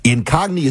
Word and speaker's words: Incogni: Incogni is spelled I-N-C-O-G-N-I. I-N-C-O-G-N-I. It's like incognito without the T-O on the end.